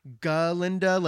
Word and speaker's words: Galinda: And this is important because in the movie and the stage production, we get Galinda saying in the classroom, like, oh, it's Galinda, like, you have to pronounce the ga, like, and she's being kind of bitchy about it.